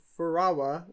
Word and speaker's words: Furawa: F- Ferrara? – For for Furawa. It's F U R A W A. I think it's Furawa. – Furawa.